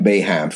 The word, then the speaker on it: Boeheim: He joins a class of Coach K, Bobby Knight, And Jim, bro, from Syracuse, Coach Bohank, Coach from Syracuse. I'm sorry, Jim Boeheim, folks. Sorry for the mispronunciation of Coach Boeheim's name.